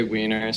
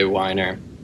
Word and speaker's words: Weiner: Some people say Weiner, some people say Weiner.